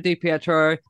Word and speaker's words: DiPietro: That's Spencer, and we're saying La Jolla to La Jolla because we're joined by Babbitt Scribe, Joe DiPietro. I'm saying that correctly, yes? – You are saying it absolutely correctly.